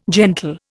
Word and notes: gentle: Indian

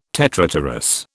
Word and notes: tetrapterous: British English

